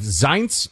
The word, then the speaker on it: Zients: does that mean that Jeff Zients, I believe I've been told is how you pronounce this,